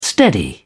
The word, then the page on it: steady: British English